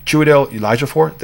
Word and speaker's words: Chiwetel Ejiofor: Starring Benedict Cumberbatch, Benedict Wong, Rachel McAdams, Mads Mikkelsen, and Chiwetel Ejiofor.